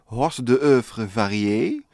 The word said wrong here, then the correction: hors d'oeuvre: Hors d'oeuvre. – Hors ja.